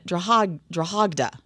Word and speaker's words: Drogheda: And Mass at St. Peter's Church in Drogheda. I need to change it because I think they say the G, like a hard G.